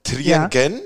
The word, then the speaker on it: Triengen: Trienge, ist das richtig? Spricht man das richtig? – Äh, ist Triengen, das ganz falsch? Triengen, ja. aber äh, das stimmt nicht ganz.